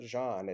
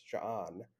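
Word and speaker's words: J'onn: And the way that Young Justice interprets that is that they like pronounce the implied stop. So it's not Jean, it's John.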